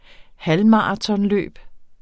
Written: halvmaratonløb

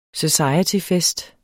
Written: societyfest

